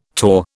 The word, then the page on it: torr: British English